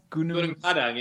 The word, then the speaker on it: Gunung Padang: Did I read it wrong that you were almost attacked in Gunung Padang? Did I say that right? – Gunung Padang in Indonesia.